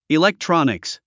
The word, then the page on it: electronics: ilektrɑ’niks; イレクトロニクス